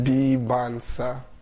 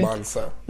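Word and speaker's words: Dybantsa: Forget the T's, they're silent. – What did he say? – Dibansa. – No, oh, should I shut up? – The T there is silent. Dibansa. – Dibansa.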